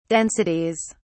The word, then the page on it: densities: British English